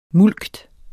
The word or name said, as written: mulkt